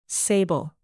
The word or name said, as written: sable